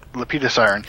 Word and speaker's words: Lepidosiren: Lepidosiren.